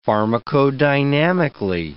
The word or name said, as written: pharmacodynamically